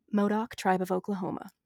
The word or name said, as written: Modoc